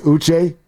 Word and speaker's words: Uche: It's pronounced Uche. Joshua Uche, I think that's how you pronounce it, and he's a solid pass-rushing linebacker.